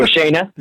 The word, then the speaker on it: Shayna: Shayna Baszler. I said it right. – Uh huh. No, Shayna, but you were close. Shayna. You said the last name right. That's what matters.